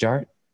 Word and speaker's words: Jart: The D is silent, so it's just Jart.